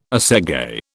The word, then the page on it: assegai: British English